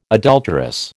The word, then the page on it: adulterous: American English